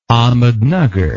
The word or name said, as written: Ahmednagar